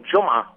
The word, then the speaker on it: Juma: And they call it, and how do you pronounce from the Arabic word, J-U-M-A-H? – That's the word Juma, which means Friday.